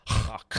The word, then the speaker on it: Loch: Guy told me, no, don't say it like that, not k at the end. Loch at the end.